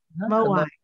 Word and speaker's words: Moai: And I hope I'm going to say this right. The Moai people. Is that how you say it? – Moai.